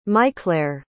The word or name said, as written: maiclaire